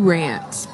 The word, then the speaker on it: Durant: Durant, baby.